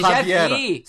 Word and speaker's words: Javier: C'est Javier. C'est en, Javier Fernandez. lopez C'est de l'espagnol. Tu prononces pas le, J.